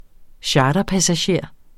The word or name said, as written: charterpassager